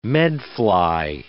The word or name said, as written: medfly